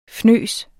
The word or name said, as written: fnys